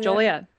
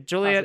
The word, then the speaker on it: Joliet: Is that how you say it? – I think so. Joliet. Yeah. All right. It's like Juliet with an O.